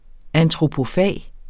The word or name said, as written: ANTROPOFAGEN